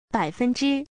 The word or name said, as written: bai3fen1zhi1